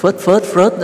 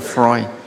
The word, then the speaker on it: Freud: Tâm học của Freud mà ta hay đọc là Freud đó, chứ thực là Freud.